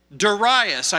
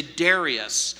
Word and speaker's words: Darius: I know that drives some of you crazy because you want to say Darius. Darius is one way of pronouncing that. Darius is the other way. Whatever version you choose.